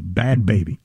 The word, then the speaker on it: Bhad Bhabie: It's spelled B-H-A-D-B-H-A-B-I-E, Bhad Bhabie.